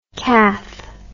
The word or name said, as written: kath